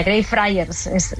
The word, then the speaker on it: Greyfriars: Así se pronuncia, Greyfriars.